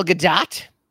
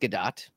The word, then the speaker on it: Gadot: Gail Gadot, and it is actually pronounced Gadot um, as I as I read online and discovered, much to Steve Hovecki's chagrin, many many years ago.